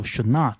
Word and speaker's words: Chouinard: 那么第一位记者呢，叫 Kevin Chenard，他这个 Chenard 的这个名字啊，非常的特别，应该是一个法语词，因为如果是英语的话呢，应该不会是有 Chenard 这一个发音。